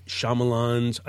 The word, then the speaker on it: Shyamalan: Night Shyamalan's, I said it correctly.